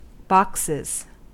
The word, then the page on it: boxes: American English